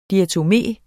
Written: diatomé

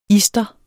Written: ister

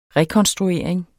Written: rekonstruering

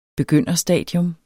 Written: begynderstadie

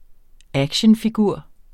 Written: actionfigur

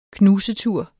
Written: knusetur

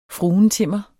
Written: fruentimmer

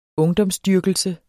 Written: ungdomsdyrkelse

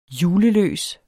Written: juleløs

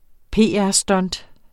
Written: PR-stunt

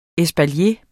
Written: espalier

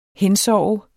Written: hensove

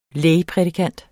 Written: lægprædikant

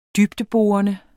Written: dybdeborende

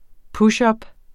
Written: pushup

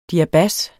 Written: DIABAS

